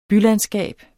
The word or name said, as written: bylandskab